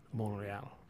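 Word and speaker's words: Montréal: I go to Montreal. That's how it's pronounced in the French. Montreal.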